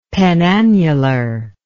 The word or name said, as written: penannular